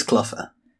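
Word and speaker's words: Clogher: I think it's pronounced Cluffer, but then it rhymes with bugger. So Clugger? It's Cluffer.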